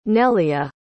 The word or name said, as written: nelia